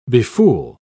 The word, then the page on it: befool: British English